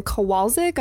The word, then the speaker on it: Kowalczyk: And so this older couple, Jim and Susan Kowalczyk. I'm not sure if I'm pronouncing that name correctly. – You got it. It's Polish.